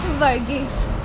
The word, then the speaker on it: Varghese: Varghese.